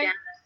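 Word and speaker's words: Jancis: Jenkins Robinson, I never know how to say her name. Did I say it right? – Janice. – Yeah, but it's J-A-N-C-I-S.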